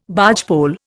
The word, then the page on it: bargepole: Indian